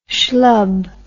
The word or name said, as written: shlub